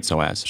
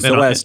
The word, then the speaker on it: psoas: it's like a too tight psoas. – Sure. – Mm-hmm. Yep. And psoas, too, is spelled really weird. It starts with a P. – Yeah. P-S-O-A-S.